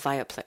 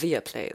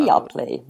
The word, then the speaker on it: Viaplay: Und ich gehe auch mal stark davon aus, also für Interessierte jetzt da draußen, ne, Viaplay, ich sage immer Viaplay, aber ich glaube Viaplay ist die Aussprache. – Viaplay, ja, Viaplay. Haben sie mir erzählt.